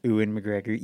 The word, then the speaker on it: Ewan McGregor: Ewan McGregor.